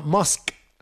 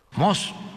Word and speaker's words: Musk: Musk. – No, menso, así no. – Haga de cuenta que va a decir mosca y le quita la A.